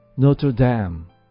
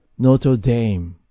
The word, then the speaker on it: Notre Dame: このノートルダムですが、ウェブスターで弾くと Notre Dame なんですが、オックスフォード系で弾いてみると Notre Dame と発音するんですね。これどちらもありということでしょうね。